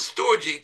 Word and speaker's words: storge: The Greek calls that type of love a storge type of love. S-T-O-R-G-E. Some pronounce it storge, some pronounce it storge.